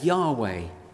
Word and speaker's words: Yahweh: Here, Lord, L-O-R-D, is translating God's name as given to Moses at the burning bush, sometimes pronounced today as Yahweh.